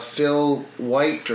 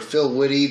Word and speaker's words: Filwite: Yeah, the production company for this one was called Phil White, or Phil Witte, depending on how you pronounce it, spelled F-I-L-W-I-T-E.